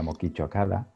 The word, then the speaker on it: Mokichi Okada: Y, bueno, te invitamos para que nos platiques de la, del legado de, de Meishusama, que en realidad su nombre era Mokichi Okada, o ya me corregirás tú la pronunciación. – Sí, es correcto. Eh, su nombre era Mokichi Okada,